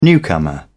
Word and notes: newcomer: British English

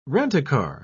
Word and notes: rent-a-car: British English

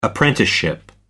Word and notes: apprenticeship: American English

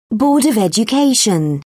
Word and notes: board of education: British English

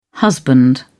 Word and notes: husband: British English

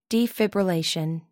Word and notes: defibrillation: American English

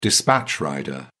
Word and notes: dispatch rider: British English

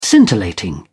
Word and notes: scintillating: British English

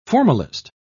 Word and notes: formalist: British English

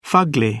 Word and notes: fugly: British English

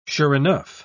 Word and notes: sure enough: British English

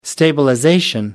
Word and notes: stabilization: American English

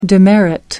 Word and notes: demerit: American English

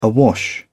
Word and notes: awash: British English

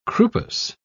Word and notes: croupous: British English

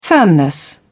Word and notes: firmness: British English

